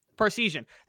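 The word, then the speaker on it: Parseghian: Is that how you pronounce his last name? I never got it right. – Parsegian.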